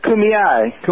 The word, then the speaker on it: Kumeyaay: the Kumeyaay, if I can remember if I pronounced it right. Are you with us? – Yes, I am, and it's Kumeyaay.